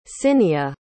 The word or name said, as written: cinnia